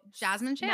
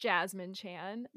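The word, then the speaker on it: Jessamine Chan: So Jasmine Chan. – Now you're in the know. You're in the know with all of us that it's pronounced Jasmine Chan.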